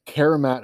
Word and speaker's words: Karamat: Karamat is spelled K-A-R-A-M-A-T, Karamat.